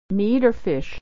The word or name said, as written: or fish